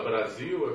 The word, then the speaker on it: Brasil: A palavra Brasil é bem representativo disso, quer dizer, se fala com som de Z, mas se escreve com, com S.